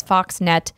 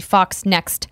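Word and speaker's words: FoxNext: PD0000 says, I think you both refer to the publisher of the Alien game as Foxnet. It's actually Fox Next. We actually talked about how we mispronounced it later in that story.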